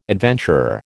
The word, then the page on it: adventurer: American English